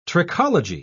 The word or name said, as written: trichology